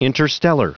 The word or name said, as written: interstellar